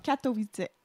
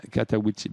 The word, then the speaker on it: Katowice: Comment tu le prononces, Katowice? – Katowice. – Katowice, ben oui.